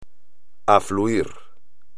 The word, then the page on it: afluir: espagnol